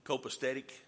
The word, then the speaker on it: copacetic: And so Samuel makes preparations, and everything is, uh, how do you say it? Syncopatico? copacetic, uh, however it is.